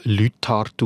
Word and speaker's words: Leuthard: Ein vergleichbarer Fall ist übrigens auch der Name von unserer Altbundesrätin Doris Leuthard. Ihr Name wird mundartlich ursprünglich Leuthard ausgesprochen.